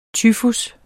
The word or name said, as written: tyfus